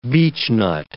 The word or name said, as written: beechnut